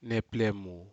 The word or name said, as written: neplemu